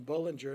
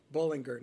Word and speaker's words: Bullinger: Bullinger, we used to call him Bullinger, and then we found out we mispronounced it, so we try to call him Bullinger now.